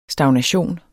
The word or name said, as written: stagnation